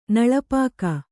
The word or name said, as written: ನಳಪಾಕ